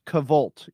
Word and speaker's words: KVLT: It's Kvolt, you know, like Henry Cavill. – Okay. There you go. Kvilt.